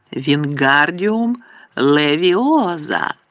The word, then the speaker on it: Вінгардіум Левіоза: «Вінгардіум левіоза!» – вигукував він, розмахуючи своїми довгими руками, немов вітряк. «Ти не так кажеш?» – дратувалася Герміона. «Має бути Вінгардіум левіоза!» «Левії треба вимовляти протяжно і легко!» «Кажи сама, коли така розумна!» – огризнувся Рон.